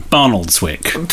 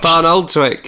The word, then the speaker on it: Barnoldswick: Barnoldswick. – Perfect. – Oh, okay, fine. What was all the fuss about? – Barnoldswick.